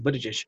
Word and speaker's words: Buttigieg: How do you pronounce Buttigieg. – His name again? Buttigieg.